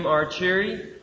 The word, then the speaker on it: Arcieri: As you said, my name is Jim Archieri, and that's spelled A-R-C-I-E-R-I. It doesn't look like it sounds, but if you just remember that I am cheery and you are cheery and then we're all cheery, then you'll have my name down.